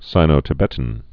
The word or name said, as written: Sino-Tibetan